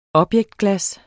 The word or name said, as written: objektglas